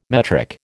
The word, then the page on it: metric: American English